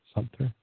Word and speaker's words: Sumter: Sumter. Sumter. I added an extra syllable in there, I think.